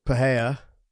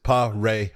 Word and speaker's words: Pereira: You know, it's like, but his last name is Pereira. – All right. – Just say Ray in the middle.